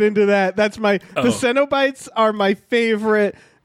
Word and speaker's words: Cenobites: Like, the Cenobites, that's how it's pronounced.